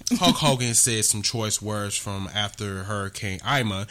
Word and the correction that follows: Irma: Hurricane Irma. – Yeah, Irma, I'm sorry.